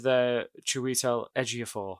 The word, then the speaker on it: Chiwetel Ejiofor: Also with Chiwetel Ejiofor. I said his name right, I think, this time. – You smashed it, mate.